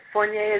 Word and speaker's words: Fournier: Fournier is perfect.